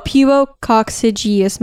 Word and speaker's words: pubococcygeus: I think it's like pubococcygeus muscles, the PC muscles. – Yeah, I'm not. You said it mostly right, but I don't want to say it wrong. – Oh, pubococcygeus muscles. – There you go.